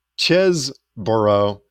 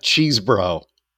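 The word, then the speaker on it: Chesebro: It's actually pronounced Chesboro, not Cheesebro. Because I've been looking at it going, oh, Cheesebro.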